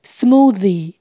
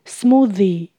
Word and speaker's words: smoothie: Smoothie 冰沙，th 舌头要伸出来。 Smoothie Smoothie Purple 紫色的，Purple Purple It doesn't look natural at all 看起来一点都不天然。